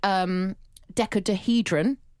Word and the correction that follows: dodecahedron: I think I've said that wrong. Decahedron? – Dodecahedron? – Dodecahedron.